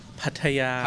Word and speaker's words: Pattaya: You got to say it like this. Pattaya.